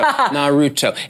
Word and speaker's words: Naruto: You know, that pronunciation. I wish Naruto. Naruto. – A Naruto run. – There we go. I got a fact check in my ear and it's good to go. Naruto.